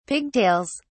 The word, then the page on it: pigtails: British English